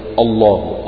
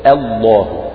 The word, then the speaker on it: الله: الله برضو هذه همزة رابعة ينبه على ترقيقها، وهي همزة لفظ الجلالة الله، لأن همزة لفظ الجلالة بعدها حرف مفخم وهو لام لفظ الجلالة، فأحيانا يطغى المفخم على المرقق فالبعض يجي ينطق خطأ فيقول الله الله تطلع همزة وهذا خطأ، همزة مرققة فلا تقول الله ولكن قل الله الله،